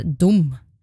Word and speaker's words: dum: En annen bokstav som henger sammen med dette er U, som også blir uttalt som O. Døme er dum.